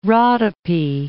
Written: Rhodope

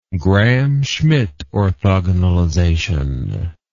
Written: Gram-Schmidt orthogonalization

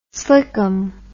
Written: slickum

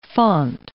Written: font

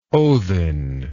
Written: othin

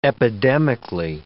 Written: epidemically

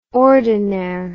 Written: ordinaire

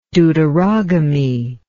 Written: deuterogamy

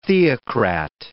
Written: theocrat

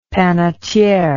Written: panetiere

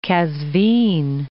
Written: Kazvin

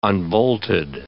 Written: unbolted

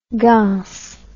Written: gance

